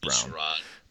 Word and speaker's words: Sherrod: So number 10 is quite frankly, I apologize because I don't quite know how to pronounce his first name, but I think it's Sherrod, Sherrod Brown. – Yeah, it's Sherrod.